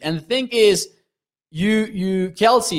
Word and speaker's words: Kelce: Thank you for the correction. Travis Kelce. My bad.